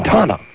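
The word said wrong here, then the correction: Thanh: Tom. – No, it's more like Tom.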